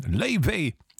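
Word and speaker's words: Laufey: Die nächste hier im Journal auf NDR Kultur habe ich, als sie aufkam, vor 4, 5, 6 Jahren wochenlang falsch ausgesprochen, weil ja eigentlich alles immer Englisch ausgesprochen wird. Lovey macht doch auch Sinn, aber Lovey heißt sie, kommt aus der altnordischen Mythologie.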